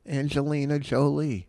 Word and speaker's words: Angelina Jolie: Angelina Jolie.